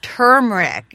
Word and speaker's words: turmeric: As a teacher, though, I have to tell you, you're pronouncing it incorrectly. It's turmeric, you know.